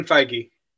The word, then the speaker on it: Feige: But then you listen to people like Kevin, I always say Feige, but I don't know if it's Kevin Feige.